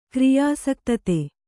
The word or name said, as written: ಕ್ರಿಯಾಸಕ್ತತೆ